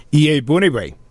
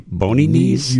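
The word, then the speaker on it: Iyiegbuniwe: Iye Bony Knees? – You need to be. No, Iye Buniwe.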